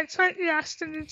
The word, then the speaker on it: tracheostomy: Tracheostomy, is that how you say it? Tracheostomy? – Yeah, tracheostomy tube.